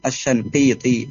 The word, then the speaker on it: الشنقيطي: Itu bacanya sangkit ya, bukan shing tapi shan, bil fathis sya bukan bil kasri. – Naam, wa'adwa'ul bayani li Muhammad bin al-Amin al-Shanqiti rahimahumullah.